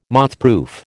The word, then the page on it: mothproof: American English